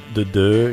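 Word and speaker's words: The Dø: I think they pronounced her name like The Doe. It's the got Doe. That little line through the O or something like that.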